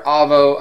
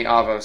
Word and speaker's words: Avo: Now, for those of you who don't know Avo, and I hope I'm pronouncing that right because I've heard Avo, I've heard Avo. I've heard Avo more, so and I've had a rep tell me Avo, so that's what I'm going with.